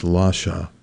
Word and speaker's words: Lasha: and also Prince Lachey. I've heard it pronounced Lasha, but I'm just going to say Lachey on flute.